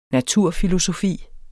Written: naturfilosofi

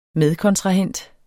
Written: medkontrahent

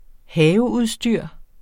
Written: haveudstyr

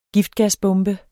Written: giftgasbombe

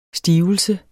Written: stivelse

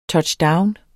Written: touchdown

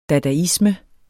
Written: dadaisme

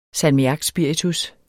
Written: salmiakspiritus

